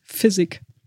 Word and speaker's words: Fizik: Heißt das jetzt Physik oder Fizik? – Physik. – Okay, wie Physik? – Meine ich. – Physik, okay. Also das ist der Physik Vitesse, ist eigentlich ein sehr günstiger, wohl auch mal als Damenrennradsattel lancierter Sattel.